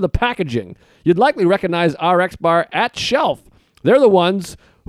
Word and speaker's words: RX Bar: The RX bar, is that how you say it? RX bar. – Yeah. – RX bar. I wasn't sure if it was Rex bar.